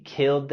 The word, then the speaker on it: killed: La terminación ed en la palabra killed lo pronunciamos con el sonido d.